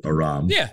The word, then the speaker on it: Aram: So I guess ethnically, if we're talking about like being Armenian and being named Aram, like, I pronounce it Aram, and that's kind of how I have everybody else pronounce it.